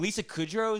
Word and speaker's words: Lisa Kudrow: And fucking Lisa Kudrow is in it, dude.